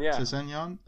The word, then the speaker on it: Sessegnon: How do you pronounce it? Sessegnon? – Sessegnon.